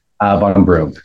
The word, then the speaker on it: VonRumpf: Wow, you said it right. – I did? – Ned. Yeah, most people butcher it. 95% of people definitely butcher the VonRumpf.